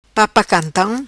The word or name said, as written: Papa cantam